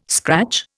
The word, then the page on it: scratch: Indian